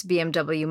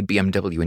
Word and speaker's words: BMW: When you say BMW in English, it sounds like BMW, but in German, they say BMW. So the W is pronounced as a V.